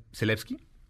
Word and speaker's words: Zelensky: siempre lo pronunció mal, Zelensky, lo hice bien.